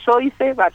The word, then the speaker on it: Joyce: Bueno, mi apellido es Joyce, lo pronunciamos así. – Joyce. – Sí, Joyce, como suena.